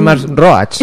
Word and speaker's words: Max Roach: Max Roach. – Eso, sí. – Lo dije bien, lo dije Max Roach, como digo siempre.